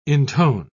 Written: intone

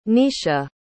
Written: Niesha

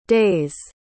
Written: day's

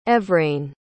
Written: Evrain

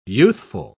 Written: youthful

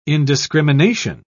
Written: indiscrimination

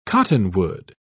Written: cottonwood

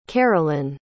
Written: carolyn

